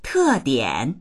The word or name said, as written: te4dian3